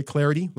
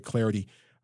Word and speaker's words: Clardy: At Troy Clarity, last name is spelled C-L-A-R-D-Y, at Troy Clarity.